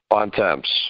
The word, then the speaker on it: Bontemps: Bontemps or Bontemps? – Bontemps. – How do you pronounce it in French? – I think the way you said it the first time. – I think it's like Bontemps or something like that in French.